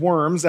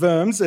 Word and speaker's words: Worms: On April 14th, 1521, Martin Luther was on his way to the Diet of Worms, not the Diet of Worms as it's spelt, it's the Diet of Worms, it's German.